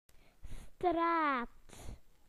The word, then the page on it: street: British English